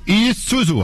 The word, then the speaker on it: Isuzu: É, Diego, você escreve em Zuzu, mas a pronúncia é Isuzu. Isuzu.